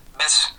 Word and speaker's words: Bysshe: By Percy, how do you say that? Bysshe? – Bysshe. – Bysshe. Percy Bysshe Shelley. – Bysshe. – I think Bysshe. It's Bysshe.